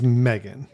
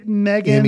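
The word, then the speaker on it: M3GAN: And that is the way you pronounce it, is Megan. – Megan. – M-E-G-A-N. – Right. – Even though the E in the title's kind of turned like three.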